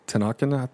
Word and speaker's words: TenHaken: And so, a quote from the mayor, Paul Ten- TenHaken, I believe is the pronunciation.